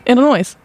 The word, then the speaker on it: Illinois: Illinois. – Illinois. – Illinois. – Illinois. The S is silent.